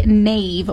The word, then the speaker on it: knave: What about a knave with a silent K in there? – Knave, that's somebody...